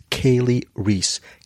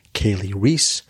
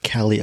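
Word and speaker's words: Kali Reis: The lead actress, her name is Kaylee Reese. K-L-I-R-E-I-S. Kaylee Reese. I pronounce it Kelly a couple of times because I'm an idiot.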